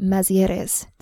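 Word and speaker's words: Mazières: Today on the show, I am so excited to have David Mazieres. Did I say that right? – No, it's French. It's Mazier, actually.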